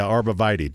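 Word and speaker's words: arborvitae: Arbivitae? Is that what that's how it's pronounced? – Yeah, arbivitae. Does it start with an A? – Yes. That's what I said, A-R-B-O. – Ballard. – Anyway, arbivitae is apparently how you pronounce this.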